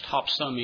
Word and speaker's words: Topsham: It's spelled with an S-H, but in Maine, we don't pronounce the S-H when it's at least in the name of the town in which the church I pastor. It's Topson, even though it's spelled Topsham.